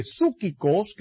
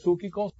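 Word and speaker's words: psúquicos (ψυχικοί): Por eso la palabra de Dios dice que para el hombre psúquicos, que es el hombre que consiste de alma, mire, la palabra es psúquicos, ¿verdad? Psúquicos.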